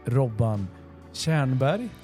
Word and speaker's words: Robban Tjernberg: Men vi måste också ta tillfället i akt att välkomna dig, Robban Tjernberg. – Snyggt, snyggt. – Rätt uttal och allt.